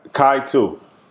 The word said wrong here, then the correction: Kitu: Did I pronounce that correctly? – What's that? – Organic coffee? – Oh, Kitu. – Kitu. – Kitu. Yeah, yeah.